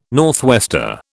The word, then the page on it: northwester: British English